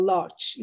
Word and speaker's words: Łódź: You know, it was New York, then Warsaw, and then Łódź, or Łódź, you know, as they pronounce it here.